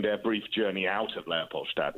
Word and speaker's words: Leopoldstadt: And we've all been pronouncing the name of this play wrong. – Yes, I think it's been said as, I mean, Tom's such a trickster because, you know, it's Leopoldstadt is the district.